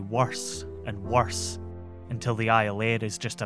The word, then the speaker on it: Iolaire: The Iolaire, Gaelic for eagle and so frequently mispronounced as Iolair that it becomes its name, is loaded up quickly with sailors who are keen to be the first foot of their loved ones on New Year's Day.